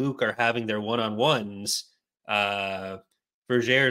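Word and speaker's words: Vergere: And there's actually in the book that we were supposed to be talking about in Destiny's Way, Vergeer, it's actually pronounced Vergeer. Onimi makes a rhyme that rhymes air with Vergeer, and it's all actually rhyming. So it has to be Vergeer.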